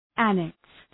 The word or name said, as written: annexed